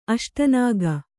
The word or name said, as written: ಅಷ್ಟನಾಗ